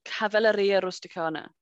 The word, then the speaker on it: Cavalleria Rusticana: And my Italian cousin told me off because I was saying it incorrectly. It's called Cavalleria Rusticana.